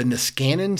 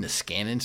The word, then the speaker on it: Niskanen: And it's the Niskanen Center. I guess that's how you pronounce it. N-I-S-K-A-N-E-N, Niskanen Center.